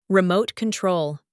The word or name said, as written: remote control